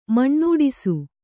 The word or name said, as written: ಮಣ್ಣೂಡಿಸು